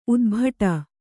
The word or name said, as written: ಊದುಬಟ್ಟಿ